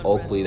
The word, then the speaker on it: Oakley: And my real name is Aaron Oakley.